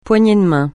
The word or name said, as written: poignée de main